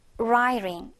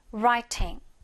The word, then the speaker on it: writing: 먼저 미국식으로 발음하면 Riring 이런 식으로 중간에 R 발음이 이 R로 약화된다. 정말 정말 많이 이야기했던 것 같아요. 그쵸? Riring. 그치만 얘를 영국식으로 발음을 한다면 T 발음을 죽이면 안 돼요. 얘를 살려서 Writing. 한번더 Writing. 이렇게 발음해주면 됩니다.